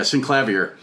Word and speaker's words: Synclavier: Yeah, synclavier.